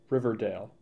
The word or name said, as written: Riverdale